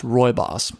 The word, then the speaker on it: rooibos: It's spelled rooibos, but it's pronounced rooibos.